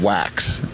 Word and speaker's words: Wachs: And today we have Kevin Wax. And which, I did spell your name wrong, and it's pronounced W-A-X-S, right? – Pronounced wax, just like surf wax? Or yeah. – Yeah. W-A-C-H-S.